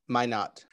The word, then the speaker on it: Minot: Minot.